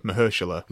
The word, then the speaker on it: Mahershala: He was either on Colbert or Jimmy Kimmel, and they broke down how to say his name. And he said Mahershala.